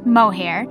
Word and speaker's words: mohair: I can't believe I forgot that, but it is pronounced mohair,